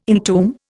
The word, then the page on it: entomb: Indian